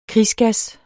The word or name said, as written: krigsgas